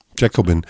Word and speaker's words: Jacobin: Das Magazin heißt Jacobin oder wie wird das ausgesprochen? – Jacobin.